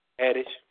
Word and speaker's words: Haddish: Is her name Hadish or Haddish? – Haddish.